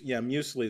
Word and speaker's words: Muesli: Yeah, something like Mueslix. – Muesli is a thing. Well, Muesli does rhyme with McGee and tree.